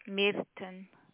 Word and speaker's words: Meerton: Rodney Middleton, was it? – Merton. – How do I spell that? – M-E-E-R-T-O-N. – Newton? – T-O-N. Merton. – Rodney Newton, is it? – Merton. M-E-E-R-T-O-N.